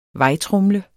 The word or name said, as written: vejtromle